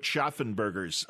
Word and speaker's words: Schaffenberger: We might know Kurt Schaffenberger's art. Did I say his name right? – That's correct.